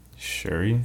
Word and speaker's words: Shuri: So, but like, if you combine like, is it Shuri or Suri? How do you say that character's name? His sister. – Shuri. – Is it Shuri? – Yeah, I think so.